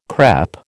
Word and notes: crÈpe: American English

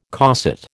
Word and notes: coset: American English

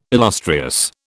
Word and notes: illustrious: British English

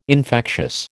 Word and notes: infectious: American English

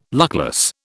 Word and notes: luckless: British English

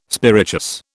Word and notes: spirituous: British English

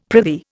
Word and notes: prithee: Indian

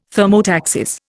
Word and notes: thermotaxis: Indian

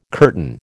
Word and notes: curtain: American English

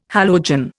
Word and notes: halogen: Indian

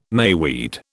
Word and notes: mayweed: British English

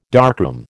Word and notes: darkroom: American English

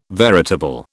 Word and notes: veritable: British English

